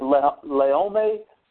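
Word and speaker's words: Laome (לאום): It's spelled, in Hebrew, it's spelled L-O-M, but it's pronounced L-E-H-O-M-E, Laome.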